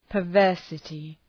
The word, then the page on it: perversity: pər’vɜ:rsətı